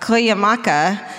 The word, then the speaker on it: Cuyamaca: and a ranger at Cuyamaca State Park. Cuyamaca! – Did you do it? – I did! – Nice! – It's actually spelled like it said.